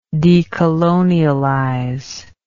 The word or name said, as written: decolonialization